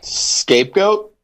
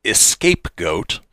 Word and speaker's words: scapegoat: Scapegoat? – Would you agree, Tony? – Yeah, I would agree with that. Yeah. – You would be correct. A lot of people mispronounce it and call it an escapegoat.